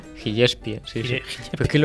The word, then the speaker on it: Gillespie: Gillespie. – Gillespie, como he dicho yo.